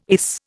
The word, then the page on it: ace: Indian